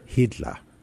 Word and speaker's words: Hiedler: If the story is true, and then when she marries this man whose surname is Hitler. – Hiedler. – How do we spell it? – H-I-E-D-L-E-R.